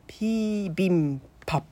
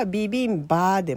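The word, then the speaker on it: ピ ービ ン パ (비빔밥): まあ、日本語で書いた方が分かりやすいとは思うんですけど、韓国語だと 3 文字でピービンパってなるんですね。なので発音の違いがあるので、これはビビンバーでもビビンパーでもピービンパーでもどれでも正解です。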